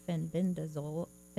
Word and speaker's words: fenbendazole: Supple Jr. is also known as Ben Fenn for his research on fenbendazole.